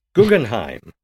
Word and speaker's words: Guggenheim: Guggenheim. – Bless you. Guggenheim.